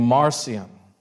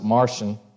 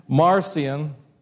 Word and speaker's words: Marcion: But Marcion, some people call it, pronounce it Martian, but I just feel like that's weird. So Marcion, and I'll spell it for you if you want to look him up and do some more reading.